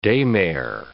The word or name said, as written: daymare